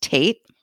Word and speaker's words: Tate: For example, the word T-A-T-E that many of us would think was pronounced Tate looks like it's pronounced today. – No, it's Tate.